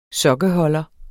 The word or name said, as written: sokkeholder